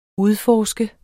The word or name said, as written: UDFORSKET